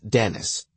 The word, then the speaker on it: Denic: Dennis, D-E-N-I-C, is pronounced Dennis.